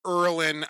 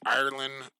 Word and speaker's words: Erlin: TD Erlin, I have to get this in, Brad. I butchered TD Erlin's name. I was saying Ireland all night long.